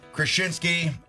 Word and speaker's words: Krasinski: Jack Krasinski. What, 100? Did I pronounce it wrong all the time? – Krasinski. Yeah, there you go.